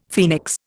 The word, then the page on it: phoenix: Indian